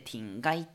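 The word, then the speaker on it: 田鶏: 鶏っていう字の前に田んぼの田でティンガイって読むん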